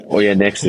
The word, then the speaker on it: Oyonnax: That's one of the ones I was trying to – it's a hard one, eh? You read it and you're like, wow, this could be pronounced so many different ways. – Yeah, Oyonnax is usually one of them. – Yeah, I would have definitely said the X.